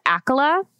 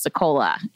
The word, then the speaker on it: Accola: I gotta say, I'm comforted by that because my maiden name is Akola, and it's spelled A-C-C-O-L-A, and no one could ever pronounce it.